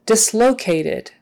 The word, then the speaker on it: dislocated: Dislocated, dislocated. Um, the emphasis on, is on the low.